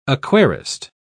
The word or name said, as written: aquarist